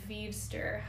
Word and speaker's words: Vevster: So, um, we had a listener question from at V E V S T E R, which I think you pronounce Veevster.